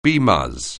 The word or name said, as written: BMus